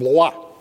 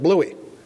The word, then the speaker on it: Blois: Officers who were relieved were sent to a chateau named Blois, B-L-O-I-S, I think it's spelled. But the Americans hacked it up and they pronounced it Bluey.